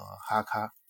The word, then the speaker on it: HK: 黑克勒克赫公司，德语呢就是 Heckler Koch，所以呢又简称 HK，呃，德语呢念成哈卡。